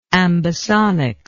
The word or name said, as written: ambisonics